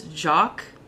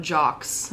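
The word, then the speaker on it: Jacques: Okay, now I can correct you, because that is Jock, not Jox. Oh. I think he's French. There's an S at the end of his name, but you don't pronounce that S, I don't think.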